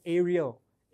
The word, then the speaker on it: Ariel: I said, "Hear, O Ariel," it rhymes with Israel on purpose, "the Lord your God, the Lord is one.